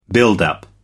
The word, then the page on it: build up: American English